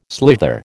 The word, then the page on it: slither: American English